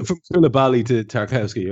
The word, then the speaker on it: Tarkowski: So we've gone from looking at Kubali of Napoli to how do you pronounce his surname? – Tarkowski, yeah. – Tarkowski of Burnley.